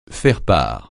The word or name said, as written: faire-part